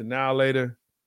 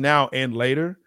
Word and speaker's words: Now and Later: Yeah, now we take the now later, put it inside, and it's actually pronounced now and later. – Oh, no. – Of course. – Don't try to now tell later. Us. It's everybody black in America say now later.